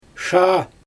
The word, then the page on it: ʃaa: Xyzyl